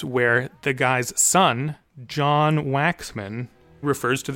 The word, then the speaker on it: Waxman: I am going to pronounce it Waxman. I get the impression from the Oscar ceremony that in 1950 he was being called Waxman by his peers.